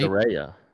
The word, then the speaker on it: Saraya: Saraya.